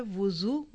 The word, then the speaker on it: विज़ू: याद रखिए दो लफ्ज़ हैं, एक है वुज़ू वाव की पेश, और एक है वज़ू वाव की ज़बर, और तीसरा लफ्ज़ है विज़ू वाव की ज़ेर के साथ। तीनों के प्रोनाउंसिएशन फ़र्क है।